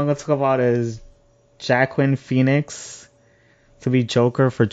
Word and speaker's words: Joaquin: Yeah, Joaquin, I think that's how you say his name. – No, yeah, Joaquin.